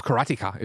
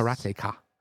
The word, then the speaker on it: Karateka: Karateka. That's probably how you pronounce it, right? You remember this game? – No. – I mean, it's karate and ka, so Karateka.